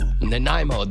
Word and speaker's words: Nanaimo: It's like Namine, what do you call those bars? Nanomi bars or Nanaimo, that's it.